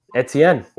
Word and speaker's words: Etienne: Etienne.